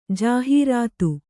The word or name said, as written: ಜಾಹೀರಾತು